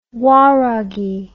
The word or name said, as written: waragi